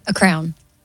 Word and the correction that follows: crayon: You said crown, but it's crayon.